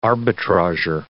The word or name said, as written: arbitrager